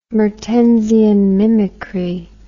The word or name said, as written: mertensian mimicry